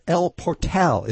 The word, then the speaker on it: El Portal: at the El Portal. Is that how you say it? – Yes. I don't know why it's not El Portal, but it's the Spanish pronunciation.